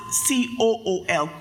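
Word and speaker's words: cool (כול): It's spelled K U L in the Hebrew. And it, you could pronounce it C O O L, cool.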